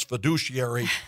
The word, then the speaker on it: fiduciary: And I want to say this, that having been in the hockey world with all those Russian and Finnish and Swedish names, you pronounce fiduciary precisely well. That's what I love. It's not fiduciary or, something of that. Fiduciary.